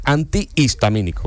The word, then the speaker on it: antihistamínico: Y hay otra que también es así, digo que yo siempre la he dicho mal, que es antihistamínico. Antihistamínico. – Es verdad, sí. es cierto. – Lleva H intercalada entre las dos.